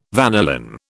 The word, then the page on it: vanillin: British English